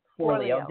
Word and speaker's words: Forleo: Forleo.